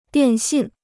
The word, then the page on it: 电信: diàn xìn